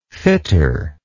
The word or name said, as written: fitter